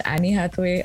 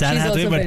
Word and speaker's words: Anne Hathaway: Internationally, Anne Hathaway, although I've heard her name pronounced Annie Hathaway, I'm not sure. – No, it's Anne Hathaway,